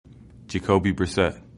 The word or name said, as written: Brissett